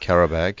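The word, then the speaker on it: Qarabağ: FK Karabag.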